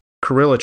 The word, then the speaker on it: Cruella: Two things we want to talk about was the Mortal Kombat trailer just released and the Cruella trailer. How do you say it? – Cruella. – Cruella. My bad.